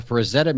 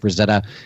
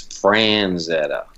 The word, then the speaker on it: Frazetta: I think the most common mispronunciation of his name is Franzetta. You know, I get a lot of people come up, oh, I love that Franzetta or Franzetti.